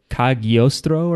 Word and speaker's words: Cagliostro: Like, would Italians technically pronounce it as like Cagliostro or something? Because it's G-O? – Yeah.